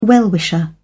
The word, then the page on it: well-wisher: British English